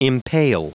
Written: impale

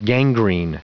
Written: gangrene

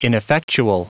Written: ineffectual

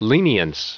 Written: lenience

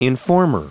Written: informer

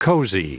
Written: cozy

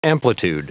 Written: amplitude

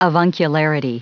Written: avuncularity

